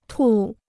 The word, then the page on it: 吐: tù